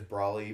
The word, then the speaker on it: Brawley: So WIL, my last name is Brawley, BRAWLEY.